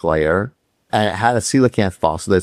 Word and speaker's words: coelacanth: As a coelacanth. Very good. Yeah, I'm impressed. – Oh, hey, there you go. I did another Fred pronunciation. So it's coelacanth, not coleocanth. – Coelacanth, yeah.